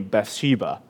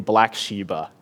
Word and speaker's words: Bathsheba: Bathsheba. But he was not able to pronounce it well, so he kept saying Black Sheba.